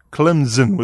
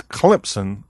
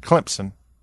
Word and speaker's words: Clemson: They call it Clemson with a Z. It's Clemson, almost like it has a P in it, Clemson.